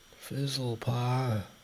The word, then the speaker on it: Fizlepuh: R-O-B-E-R-T. – Uh-huh. – And your last name? – Fizzle Paw. – Would you spell that for me, please? – F-I-Z-L-E-P-U-H.